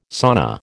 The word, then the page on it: sauna: American English